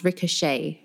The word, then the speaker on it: ricochet: So you might think it's ricochet or ricochet, but it's one of those French words that has the E-T at the end. So it has more of an A sound, ricochet.